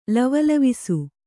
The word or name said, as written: ಲವಲವಿಸು